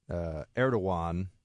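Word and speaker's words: Erdoğan: And Turkish President Erdogan, remember G's are kind of pronounced like W's from what I know in Turkish, and the C with a little weird mark under it, like it looks like Incirlik, it's Injirlik, it's like a J.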